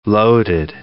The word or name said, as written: loaded